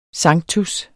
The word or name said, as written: sanctus